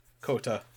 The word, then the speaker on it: Kohta: Shun Koto. Ro. Koto. – Kota. – Kota. – So many extra syllables.